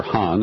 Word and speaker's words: Khan: Just over this past weekend, we learned here in the West that AQ Khan, or Han, as they would pronounce it in Pakistan, was essentially relieved of most of the elements of his house arrest.